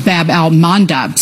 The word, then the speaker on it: Bab al-Mandab: Bab al-Mandab Strait. – You did pronounce it right.